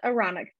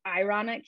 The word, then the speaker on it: Aaronic: Yes, so he has the Aaronic priesthood. Sometimes they pronounce it ironic here a lot, like people just mispronounce it, and I always laugh and I tease my husband.